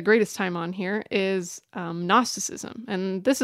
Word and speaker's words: Gnosticism: And it, the word Gnosticism, spelled G-N-O, silent G at the beginning, comes from the Greek word gnosis, which means knowledge.